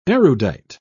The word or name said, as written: erudite